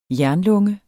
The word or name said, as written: jernlunge